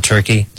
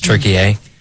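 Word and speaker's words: Türkiye: That's how we pronounce it nowadays, not Turkey. It's Turkey A.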